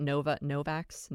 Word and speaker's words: Novavax: Novavax.